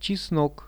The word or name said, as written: chisnok